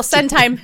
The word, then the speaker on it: Centime: Like that for like Well, Centime kind of rhymes with design.